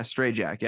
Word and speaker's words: Strajack: Strajack.